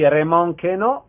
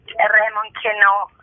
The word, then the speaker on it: Raymond Queneau: Raymond Queneau,